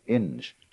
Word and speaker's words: Inge: His name was Ralph W. Inge, or Ing. Ing, I think it's pronounced.